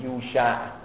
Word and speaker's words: یوشع: درستش در تلفظ عبری به فتحش اینه یوشع که ما میگیم یوشع ابن نون، این همونه یوشع وسیعه بلا فصل موسی هست.